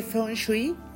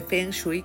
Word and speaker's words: Feng Shui: El día de hoy, el tip mágico que les quiero ofrecer es de Feng Shui o de Feng Shui, como ustedes prefieran pronunciarlo. Y es muy básico, es muy fácil, es muy sencillo y es muy efectivo.